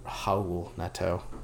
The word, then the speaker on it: Raul Neto: I had to check the pronunciation one last time so I don't disrespect Cavaliers soon point guard Howl Neto.